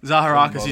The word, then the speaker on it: Zaharakis: Zaharakis is the correct pronunciation of your name? – Oh, well, people call me Zaharakis. I've had it my whole life. Doesn't bother me.